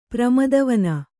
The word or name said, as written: ಪ್ರಮದವನ